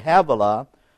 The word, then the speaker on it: Havilah: Havilah, that's pronounced H-A-V/A/L-A, Havilah, unto Sur, S-I-R, Sur, that is before Egypt, as thou goest towards Assyria, and he died in the presence of all his brethren.